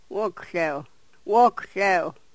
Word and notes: wokhlew: yurok

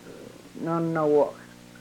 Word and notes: nonowok: Yurok

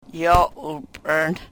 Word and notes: yo u-prn: Yurok

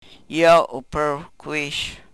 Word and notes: yo u-prwkwish: Yurok

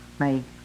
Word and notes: mey: Yurok

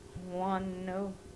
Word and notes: wonu: Yurok

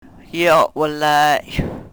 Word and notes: yo we-ley: Yurok

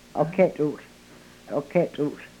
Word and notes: O Ketul: yurok

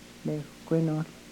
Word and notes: lewkwenohl: Yurok

